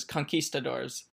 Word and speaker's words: conquistadors: the way, like, I think I've always heard it as conquistadors, or I guess in Spanish, conquistadores. Yeah. – Sure. Maybe, maybe I've just, maybe just, I've got the wrong just, pronunciation there. No, you don't have wrong. It's just different, and I thought it was interesting to point out.